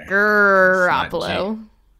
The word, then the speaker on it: Garoppolo: I mean, his name is Gar, not Gur, anyway. – Garoppolo. – It's not G-R-oppolo, it's G-A.